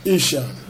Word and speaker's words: Ishan: Ishan. – Can you spell it? – I-S-H-A-N.